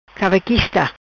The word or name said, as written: Cavaquista